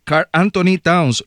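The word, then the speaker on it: Karl-Anthony Towns: Marco, te pregunto, no sé qué edad tiene, pero está este muchacho, Carl Anthony Towns, lo dije bien. – Sí, así mismo es.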